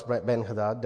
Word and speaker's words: Ben-Hadad: The king of Syria, Ben-Hadad, I'm going to pronounce Ben-Hadad, there are other pronunciations, please bear with me.